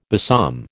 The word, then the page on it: besom: American English